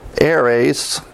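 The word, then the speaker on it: Eres (אֶרֶץ): I don't know if I can pronounce this correctly, but it's Eres. And it's spelled E-R-E-S.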